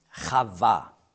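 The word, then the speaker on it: Chava (חוה): Auf Hebräisch spricht man aus Chava. Er nannte sie Chava. Auf Deutsch sagen wir Eva.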